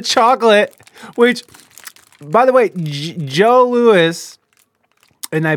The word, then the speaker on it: Joe Louis: I prefer Joe Louis, pronounced Joe Louis.